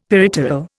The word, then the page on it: peripteral: Indian